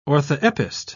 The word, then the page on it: orthoepist: British English